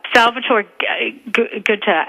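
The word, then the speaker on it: Salvatore Giunta: I read on Twitter that you just met the Medal of Honor, and I'm going to butcher his name, but Salvatore Ginta, is that right? – Salvatore Ginta, and he is amazing.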